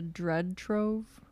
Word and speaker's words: Dread Trove: We were talking about, like, I always say it wrong. Is it the Dread Trove? – Yeah.